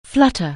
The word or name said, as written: flutter